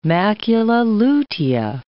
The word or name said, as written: macula lutea